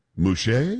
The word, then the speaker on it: Mushait: Mouche? – Yes.